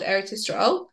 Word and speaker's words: Eretz Yisrael: Eretz Yisrael are safe.